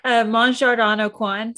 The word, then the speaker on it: Mon Jardin au Coin: Mon Jardin au Coin. So it's spelled MonJardin. AuCoin. – Yeah, so I definitely would have pronounced that coin.